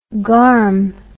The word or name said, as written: garm